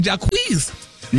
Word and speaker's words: Jacquees: Jacquees.